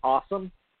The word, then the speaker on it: Ossum: It's spelled O, like, it's like O-S-S-U-M or something, but it's pronounced Awesome.